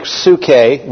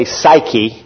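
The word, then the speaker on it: psuche (ψυχή): Your soul comes from the Greek word, well, it's pronounced in the Greek psuche. We say psyche, where you get psychology.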